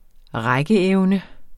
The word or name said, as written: rækkeevne